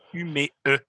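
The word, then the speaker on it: Umeå: Avant que je parle de Black Lion Records, Félix, il dit le rond sur le A, ça se prononce E. Donc, le band, la place d'où ils viennent, c'est UME E.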